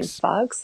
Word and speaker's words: Fuchs: I don't know how you say her last name. Fuchs.